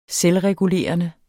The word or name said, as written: selvregulerende